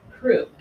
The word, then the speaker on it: croup: And he wants to study croup, actually. That's how you say it. Like the coffee that some infants croup.